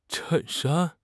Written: chen4 shan1

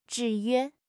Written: 制约

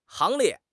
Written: 行列

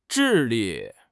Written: 智力

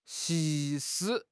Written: 溪

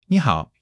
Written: ni3 hao3

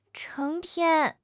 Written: 成天

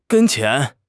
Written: 跟前

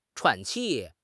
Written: 喘气

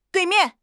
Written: dui4 mian4

